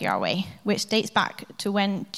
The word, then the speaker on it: Yahweh: And scholars have made the educated guess that we pronounce this name as Yahweh. But the vowels in the word are, in fact, just a guess, because only the consonants were written down.